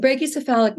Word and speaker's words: brachycephalic: Yeah, brachycephalic.